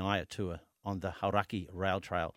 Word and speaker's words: Hauraki: Look in the notes here. It's got the pronunciation laid out. – Yeah, Hauraki. – Hauraki.